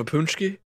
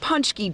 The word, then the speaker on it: pączki: The promise of a pączki? Definitely will. – To get their hands on these tasty treats. – It's Pączki Day at Weber's Bakery.